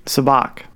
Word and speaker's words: Sabacc: Am I pronouncing it right? Sabacc? – Sabacc.